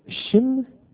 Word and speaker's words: Shin: Son point est inscrit au milieu. Se prononce Shin. Et cette lettre-là se continue de se prononcer Shin tant que le point est inscrit au milieu. Aussitôt que le point est envoyé à gauche, le H disparaît et se prononce Sin, soit mot avec lequel les Anglais ont fait le mal, le péché.